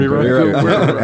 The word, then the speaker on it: Gurira: And Danai Gurira, I always say her name wrong. – Gurira as Okoye.